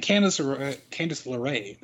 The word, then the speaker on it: Candace LeRae: Candace LeRae, that's how you pronounce that, posted on social media the other day.